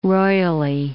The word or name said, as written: royally